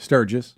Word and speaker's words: Sturgis: You were over in Sturgis, Mississippi. Am I saying that right? – Sturgis. – Sturgis, Mississippi.